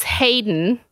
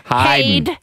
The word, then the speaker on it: Haydn: His name is Hayden, and it's spelled H-A-Y-D-N. Hayden.